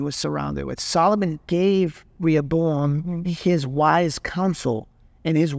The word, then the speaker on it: Rehoboam: We find Rehoboam. Still saying it wrong. – Rehoboam.